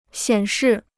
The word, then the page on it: 显示: xiǎn shì